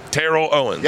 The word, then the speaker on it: Terrell Owens: I remember hugging Terrell Owens. Did I say it right? – Yes. – Terrell Owens.